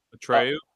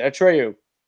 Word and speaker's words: Atreyu: Atreyu. Uh, Atreyu. That's it. Atreyu.